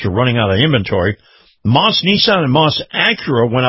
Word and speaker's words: Maus: Now, Moss is the largest selection in the state right now, and before you buy elsewhere, check them out. See Moss, M-A-U-S is how you spell it, M-A-U-S.